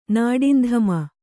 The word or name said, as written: ನಾಡಿಂಧಮ